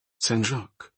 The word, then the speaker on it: Saint-Jacques: Saint-Jacques, she completed, using the French pronunciation of Saint.